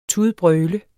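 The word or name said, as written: TUDBRØL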